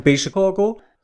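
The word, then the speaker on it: Baixo Corgo: Also geschrieben wird's Baixo Cargo, aber es wird bestimmt anders ausgesprochen. – Ich würde sagen Baixo Cargo.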